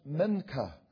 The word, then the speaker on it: mincha: It is pronounced mincha, and it simply means an offering or a present.